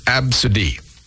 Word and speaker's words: ABCDE: How about ABCDE? Pronounced Absidy.